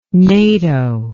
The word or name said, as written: NATO